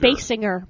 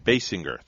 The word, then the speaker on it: Basinger: But the name is spelled C-O-L-L-I-N. – E-N. – Oh, E-N. – E-N, yes. Kim, is it Basinger or Basinger? – Basinger. – Basinger, thank you.